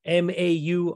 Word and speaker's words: Mauritius: I believe it's pronounced Mauritius. I had not heard of this country. Apparently, it is an African country. I had to go to Wikipedia to hear the pronunciation, and I forgot to double check it before we started recording, but I believe that's how you say it. It's Mauritius.